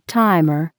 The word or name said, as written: timer